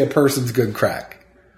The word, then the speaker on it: craic: It's good crack. – Good crack. – It's Irish slang. – Oh, I didn't know. – Yeah. But it's spelled C-R-A-I-C.